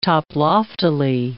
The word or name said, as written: toploftily